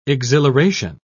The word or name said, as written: exhilaration